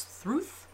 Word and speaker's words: Thrud: And he has a daughter whose name is Thruth, although it's spelt Thrud, so don't ask me.